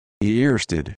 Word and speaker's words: Ørsted: Earsted.